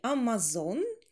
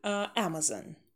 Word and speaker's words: Amazon: И Amazon, Amazon. Произносим, первая у нас лягушка, челюсть вниз, получается такой открытый A, Amazon, Amazon. The Amazon River, и тоже the, перед гласной, the Amazon River.